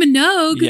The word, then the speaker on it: Minogue: It's Monday Blues by, correct me if I'm wrong on this pronunciation, Kylie Minogue. – Kylie Minogue? – Yes.